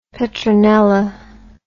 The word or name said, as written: petronella